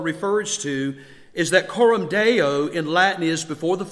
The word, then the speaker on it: coram deo: And then I was thinking how that there is a Latin term that is used and it's pronounced coram deo.